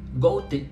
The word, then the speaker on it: Goethe: Tender con un esfuerzo ininterrumpido, dice Goethe, Goethe, es correcto la pronunciación en español, en el principio del segundo Fausto hacia la más alta forma de la existencia.